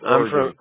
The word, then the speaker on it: Oregon: No, it's like Oregon. It's Oregon.